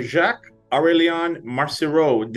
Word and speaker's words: Jacques-Aurélien Marcireau: I'm happy to be joined today by Jacques-Aurélien Marcireau.